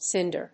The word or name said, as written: sinder